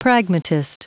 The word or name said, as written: pragmatist